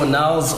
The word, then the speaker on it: Onelz: Like, so Onels, O-N-E-L-Z, for anyone that's wondering how to pronounce and spell the name. Onels is Onelensabo, born Onelensabo.